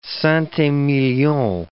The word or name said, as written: Saint Emilion